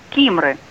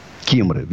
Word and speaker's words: Кимры: А у нас город Кимры. Здравствуйте. – Здравствуйте. Ну, немножко неправильно вы сказали. Кимры. – Кимры. Виноват. – Ударение на первом слове.